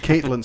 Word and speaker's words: Catelyn: Now, what about Catelyn Stark? – Mm-hmm. – Actually, remind me. I just don't know the first name. – Okay. They pronounce it Catelyn.